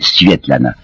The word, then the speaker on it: Svetlana: Je crois que c'est une manie russe de donner des diminutifs, alors elle s'appelait, il faut prononcer paraît-il, Svetlana.